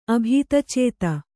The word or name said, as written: ಅಭೀತಚೇತ